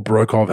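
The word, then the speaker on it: Broekhoff: National team stuff, Ryan Brokerov, or Brokov, how it's actually pronounced, pro, and Isaac Humphries have pulled out.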